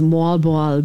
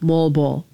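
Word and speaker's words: Moalboal: Okay, so the pronunciation I got off the internet is Mualbual, but you're calling it Mualbol. So it's whatever you want to call it. Or what? How do the locals say it? Mualbol. – Yeah, the locals call it Mualbol.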